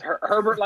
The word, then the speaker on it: Herbert: Is it Abe or Herbert? – No, it's Herbert. – Okay, so it's Herbert like Sherbert.